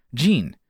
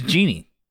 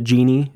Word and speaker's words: Jeanne: She spelled, she spelled her name J E A N N E. To me, that looked like Jean. Okay. It's Jeannie. Yeah. That's how like that's how she you pronounce the, her name. And Jean, I'm like, why didn't you ever correct me?